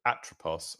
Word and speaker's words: Atropos: Funnily enough, just as a linguistic point, so the way you pronounce ancient Greek is you put the emphasis on the second syllable or the first syllable if there's only 2 syllables in the word. So Atropos would be Atropos, how you'd actually say it.